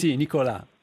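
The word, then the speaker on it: Nicola: Cioè io in questi giorni sto leggendo un libro che non so come si pronunci, credo Nicolà Gardini. – Sì, Nicolà.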